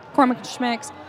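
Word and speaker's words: McCormick & Schmick's: I mean, your typical ones would be like McCormick and Schmick's. Yep. Am I saying that right? – Yeah. – Okay. McCormick and Schmick's.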